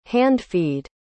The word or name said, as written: hand-feed